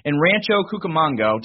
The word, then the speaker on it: Rancho Cucamonga: You grow up in Rancho Cucamonga.